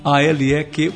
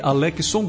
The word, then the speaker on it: Aléquison: Basta você pesquisar por lá o meu nome, da maneira que fala, se escreve, né? Aléquison, Aléquison Gomes,